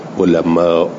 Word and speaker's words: علماء: آره دیگه گفتم، مثلا هر وقت که الف بود و همزه، الف اماله می شود به واو، علماء. نه همزه رو تلفظ میکنیم ولی الف رو اماله به واوش بای بکنیم و ولذا اینجور که الان توی این کتاب نوشته غلطه.